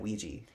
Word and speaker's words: Ouija: And I've always said it Ouija. – So Ouija is the actual pronunciation of it. Ouija is how it originated. Ouija is just what we've turned it into because we change everything to our aesthetic.